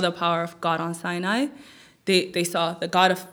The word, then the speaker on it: Sinai: So when people, saw God on the mountain of Sinai, right? Did I say it right? – Sinai.